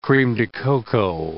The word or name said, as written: creme de cacao